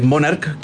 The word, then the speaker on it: Monarch: Ya la CH en Italia se pronuncia como una K. sobre sí. Entonces es Monarch, que viene de monarca o el rey de los calcetines.